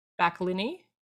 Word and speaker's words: Backlinie: Susan uh, Backlinie. I think that's how you say it. Yeah. Yeah.